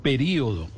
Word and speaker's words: período: Si le, si le marca la tilde en la I es porque seguramente lo pronuncia en cuatro sílabas. Periodo. Y si no le marca tilde seguramente lo pronuncia en tres sílabas.